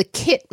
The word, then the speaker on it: Keitt: It's the Kit mango. – I can't believe you're excited to talk about a mango I've never heard of. – I'm really excited about this. So it's spelled K-E-I-T-T,